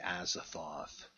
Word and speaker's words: Azathoth: So I say Azathoth.